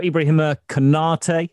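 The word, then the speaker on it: Ibrahima Konaté: So Ibrahima Konate, I think that's the correct pronunciation.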